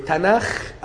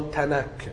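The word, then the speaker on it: تناخ (תנך): المهم هم في الآخر اليهود بينطقوه تناخ أو تناك بحسب، يعني في ناس ما بيعرفوش ينطقوا، الأجانب ما بيعرفوش ينطقوا الخاء فبينطقوا كاف.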